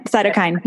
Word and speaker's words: cytokine: Cytokine. Is that the way you pronounce it? Cytokine. – Cytokine.